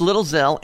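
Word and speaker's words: Lil Xxel: It's featuring Little Zell, which is spelled X-X-E-L. Am I right on the spelling? – Yes, sir. – X-X-E-L, but it's pronounced Little Zell and Chance the Rapper.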